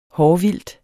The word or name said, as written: hårvildt